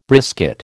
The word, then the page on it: brisket: American English